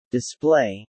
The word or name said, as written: display